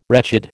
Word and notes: wretched: American English

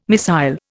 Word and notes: missile: Indian

